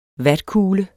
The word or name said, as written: vatkugle